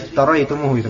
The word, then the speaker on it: iftaraitumuhu: Ya, alladhi iftaraitumuhu, itu bacanya.